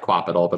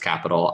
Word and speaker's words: Qapital: It's spelled Q-A-P-I-T-A-L. It looks like Quapital, but it's pronounced Capital.